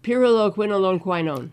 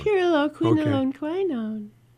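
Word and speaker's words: pyrroloquinoline quinone: Pyroloquinolone quinone.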